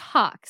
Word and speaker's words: Haack: My Instagram handle is Rachel Hawk. And so it's spelled RachelHaack. My last name looks like Haack. – Yeah. Haack. – Yes. – But pronounced Hawk.